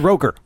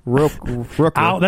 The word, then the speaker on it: Rooker: Michael Rooker, Michael Rocker. How did you say it? – I said Roker. – Rooker. – That's Al Roker.